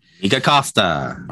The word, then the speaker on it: Nikka Costa: I should look it up, but it is Nikka Costa, right? – Nikka Costa.